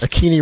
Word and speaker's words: Akini: Akini rhymes with meanie.